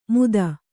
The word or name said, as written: ಮೂಧ